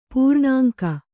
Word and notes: ಪೂರ್ಣಾಂಕ: Kannada

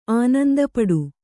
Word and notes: ಆನಂದಪಡು: ānandapaḍu

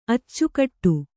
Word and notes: ಅಚ್ಚಿಕೊಡು: Kannada

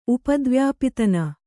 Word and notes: ಉಪದ್ವ್ಯಾಪಿತನ: upadvyāpitana

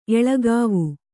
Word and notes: ಎಳಗಾವಿ: Kannada

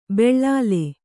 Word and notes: ಬೆಳ್ಳಾಲೆ: beḷḷāle

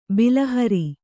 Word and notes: ಬಿಲಹರಿ: bilahari

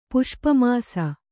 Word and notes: ಪುಷ್ಪಮಾಸ: Kannada

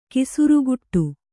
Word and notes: ಕೆಸರ್ಗೂಡು: kannada

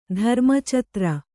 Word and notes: ಧರ್ಮಚತ್ರ: dharma catra

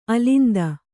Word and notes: ಅಲಿಂದ: alinda

